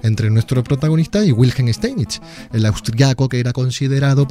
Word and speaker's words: Steinitz: de Zuckertot y con el gran maestro Wilhelm Steinitz. ¿Lo he dicho bien? – Steinitz.